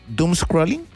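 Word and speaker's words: doomscrolling: Hoy con nuestro columnista Francisco Parada, ahí en torno a las 8:30 de la mañana, vamos a estar conversando del Doom Scrolling, ¿lo dije bien? Doom Scrolling, bueno, es doble D-O-O-M-S Scrolling, bueno, sí, está bien dicho.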